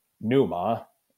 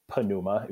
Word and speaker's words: pneuma: Because if the word was pneuma, right, or pneuma, if you like your silent P on the front, right, then we're talking about spirit.